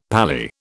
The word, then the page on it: Pali: British English